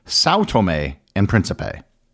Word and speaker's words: São Tomé and Príncipe: For the rest of this episode, I'm going to use the English pronunciation, which basically ignores the diacritical marks. So it will be São Tomé and Príncipe.